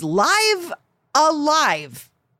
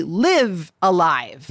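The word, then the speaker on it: Live A Live: Because that sounds like, you know, Live Alive sounds like a thing, but it's Live Alive. Like rhymes with, according to Screen Rant, Dive a Dive.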